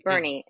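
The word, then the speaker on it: Boerne: I'm going to ask you just before I get in some stories from the San Antonio, and how do you say the other town? – Bernie. It's an insane level of vowels in that Yeah, it's a lot.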